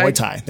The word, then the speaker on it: Muay Thai: I never fucking pronounce that. – Muay Thai? – Muay Thai. There we go.